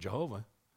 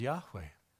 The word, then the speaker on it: Yahweh: But they don't have a J or a V in the original Hebrew language. Those letters don't exist. So I can tell you for sure his name isn't Jehovah. His own people couldn't have pronounced his name. That would have been problematic. Okay, his name was Yahweh.